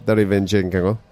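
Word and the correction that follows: Derevyanchenko: I can't do the pronunciation. Derevianchenko. – Nice.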